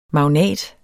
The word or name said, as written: MAGNATS